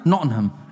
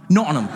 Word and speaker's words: Nottingham: Like, we learned that we've been saying Nottingham wrong. Everyone's like, yeah, you've been saying it wrong. Do you want to say it? Do you know the right way to say it now? – Nottingham.